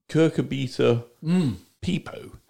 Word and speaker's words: Cucurbita pipo: I mean, Cucurbita pipo, which, you know, that's a Latin name, I think I pronounced that. – It is. Indeed.